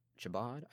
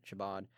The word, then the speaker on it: Chabad: I'm going to have a conversation with Matthew Bogan, who is the president of Chabad, I think is how you pronounce it, Chabad, which is the Jewish club here on campus, as well as the College Conservatives.